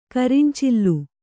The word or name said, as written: ಕರಿಂಚಿಲ್ಲು